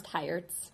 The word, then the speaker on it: Heuertz: And you pronounce it Hertz? – It's pronounced Hiertz. – Not even close. – That was like a rental car, maybe.